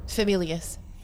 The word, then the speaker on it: Famulus: How do you pronounce that? Famulus? – Famulius. – Famulius.